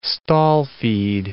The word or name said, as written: stall-feed